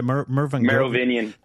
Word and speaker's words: Merovingian: into a site that the Merv, I can never say it right. The Merovingian.